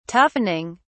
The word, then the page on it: toughening: British English